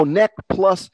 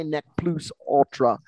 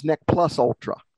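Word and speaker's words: ne plus ultra: It actually is a short U, I believe. Neck plus. In English, and I guess what we're saying, yes, Nainette, if you pronounced it in Latin, you might say neck plus ultra. But in English, it becomes neck plus ultra a lot of times.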